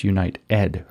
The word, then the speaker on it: UnitEd: So it's United is how you spell it, but it's Unite Ed, like education.